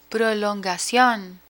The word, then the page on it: prolongacion: Spanish